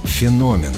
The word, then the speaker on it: феномен: Запомнить правильное ударение поможет простая рифма. «Космос дивен и огромен» — назовем его «феномен».